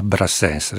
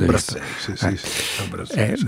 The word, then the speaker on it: Brassens: Eh sì, Brassens, la prima donna, in questo caso Alberto Patrucco, che la rifà tradotta in italiano dal suo album A Brassens. Adesso ho detto giusto. – A Brassens.